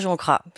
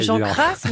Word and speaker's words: Jean Cras: Mais pas Jean Cras, c'est vrai. – Non. – Dites-le. – On a un descendant qui nous a dit comment prononcer. – Oui, mais j'arrive pas à bien faire la fin de Jean Cras, mais il faudrait le faire entre le S et le Z, d'après ce qu'on a compris.